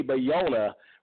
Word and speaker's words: Bayona: Bayona. Excuse me, I keep mispronouncing his name. J.A. Bayona, written by Patrick Ness and based on his novel.